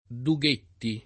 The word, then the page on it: Dughetti: lingua italiana